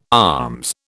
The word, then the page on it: alms: British English